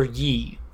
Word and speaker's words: Ye: Is it pronounced Ye or Ye? – Ye. Like Kanye. Ye.